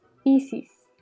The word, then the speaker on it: Isis: Una vez iniciada la concentración, se debe pronunciar el mantra de los misterios isíacos, Isis, que se pronuncia dividiendo en dos sílabas, Is, Is, y alargando los sonidos de este modo. Is. Is. Pronunciando la S como un silbo dulce y apacible.